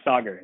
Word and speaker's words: Sagar: Yeah, Sagar, yeah.